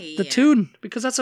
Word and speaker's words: Toon: The Toon? – The Toon. – Spell it. – T-O-O-N. – So Toon, like cartoon. – Like cartoon. The Toon.